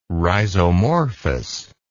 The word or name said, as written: rhizomorphous